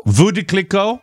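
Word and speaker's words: Veuve Clicquot: How do you pronounce that? Veuve? Clicquot. – Vous de Clicquot. – That's it. – Yes.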